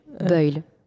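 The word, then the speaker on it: Buyl: Buile. – Oui. – On va prononcer à la française.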